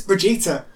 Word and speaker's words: Brigitte: And that's apparently, if you pronounce it in Sweden, it's Brigitte.